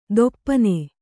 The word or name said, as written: ಥೊಪ್ಪನೆ